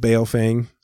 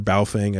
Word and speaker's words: Baofeng: He said that, have I ever heard of a company called Baofeng, or Baofeng, I believe it's pronounced. I always say it wrong.